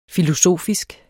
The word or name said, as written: filosofisk